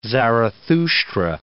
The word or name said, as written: Zarathushtra